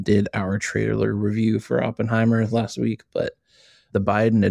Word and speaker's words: Oppenheimer: And so right now it's Oppenheimer, which we've been pronouncing this wrong the whole time. I found out today it's Oppenheimer, not Oppenheimer.